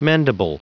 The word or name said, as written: mendable